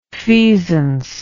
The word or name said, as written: feasance